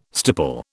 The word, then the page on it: stipple: British English